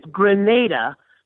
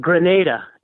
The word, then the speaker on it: Grenada: It's Grenada. Kind of like, remember the word grenade, like you're going to throw a grenade? – Mm-hmm. – Grenada is the way you pronounce that island.